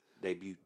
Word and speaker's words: debut: Hey, did you also know you don't pronounce the T in debuting? – Shut up. Debut.